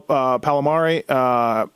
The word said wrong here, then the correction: Palmieri: Kyle Palmieri? – Palmieri. – How did you just say that? – Palmieri.